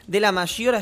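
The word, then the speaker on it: Della Maggiora: Está bien pronunciado, debería ser de la Maggiora, pero de la Maggiora. No vas a italianizar tanto porque si no te dicen, pareces el personaje de Capusotto.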